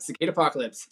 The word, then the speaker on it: Cicadapocalypse: Did I say that right? Cicada Apocalypse? – Yep, Cicada Apocalypse. Cicadas and Apocalypse combined.